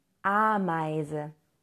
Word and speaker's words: Ameise: Die Ameise. Ameise. Und da haben wir das EI, das ausgesprochen wird wie Ei.